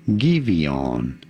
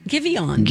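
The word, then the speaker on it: Giveon: How do you go about pronouncing his name? Givion. – Givion.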